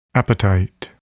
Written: appetite

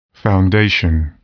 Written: foundation

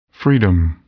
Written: freedom

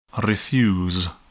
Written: refuse

